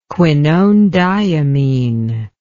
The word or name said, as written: quinonediimine